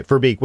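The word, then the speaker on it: Lotte Verbeek: Lot Verbeek, is that how you pronounce her name? Verbeek? Or is it Verbeek? – Yeah. – Okay, Verbeek, we'll just go with that.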